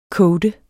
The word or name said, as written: coate